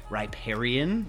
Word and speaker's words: riparian: Oh, yeah, we learned riparian. – Riparian. Let's say it like that. – Reptilian, but kind of close.